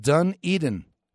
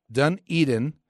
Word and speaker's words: Dunedin: He says, Bob, it's pronounced Dunedin. Dunedin. Love the show and the encouragement you send.